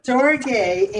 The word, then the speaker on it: storge: Storge, is that how you say it? – Yeah, okay. – Storge, and that's a love that is specifically for parents and children.